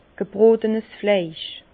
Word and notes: gebrotes Fleisch: Bas Rhin; Herrlisheim

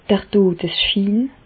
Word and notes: d'r Dodesschin: Bas-Rhin; Reichshoffen

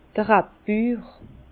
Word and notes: d'r Rabbür: Bas Rhin; Herrlisheim

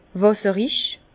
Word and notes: wàsserig: Bas-Rhin; Herrlisheim

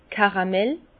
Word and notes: Karamel: Alsatian; Bas-Rhin; Herrlisheim

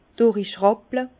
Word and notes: durichràpple: Bas-Rhin; Herrlisheim

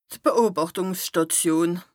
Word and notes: d' Beobàchtùngsstàtion: Bas Rhin; Strasbourg

